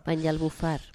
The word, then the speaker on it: Banyalbufar: 170 litros por metro cuadrado en Banyalbufar, eso no sé yo pronunciarlo muy bien, Banyalbufar. – Banyalbufar.